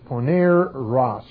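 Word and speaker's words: poneros (πονηρός): Now, the word evil is the word P-O-N, long E, poneros.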